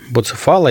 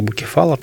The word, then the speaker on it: Буцефал: Сегодня же день рождения коня Буцефала или Букефала, там ну, разно, да. по-разному произносится, то есть коня Александра Македонского.